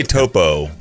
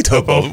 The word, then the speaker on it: Topo: I would say Topo. – Yeah? – I would say Topo. We'll go long O. Go toe.